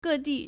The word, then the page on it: 各地: gèdì